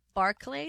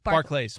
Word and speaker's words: Barclays: Is it Barclay? – Barclays. – Barclays. – But is it pronounced Barclay Center? – No, Barclays. No? Barclays. Okay.